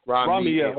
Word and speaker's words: Rahme'el: I want to make sure I'm pronouncing his name right. – Yeah, Rahme'el. – Rahme'el, okay.